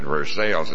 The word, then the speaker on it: Versailles: They pronounce it Versailles.